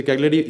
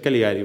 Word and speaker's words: Cagliari: Cagliari que dijiste Cagliari, iba a decir Caligari, güey.